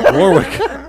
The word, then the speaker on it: Warwick: No, because the Warwick. W is silent in Warwick. – Not both of those. – Warwick. – Only the second one.